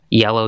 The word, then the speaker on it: YLLW: Yellow, Yellow, Yellow, that's on there. It's spelled Y-L-L-W, etc.